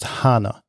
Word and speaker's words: Hana: So it's pronounced Hana, and Hana's name in Japanese means flower.